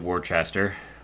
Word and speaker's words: Worcester: He goes down to AAA Worcester, I should say, right? That's how it's properly pronounced.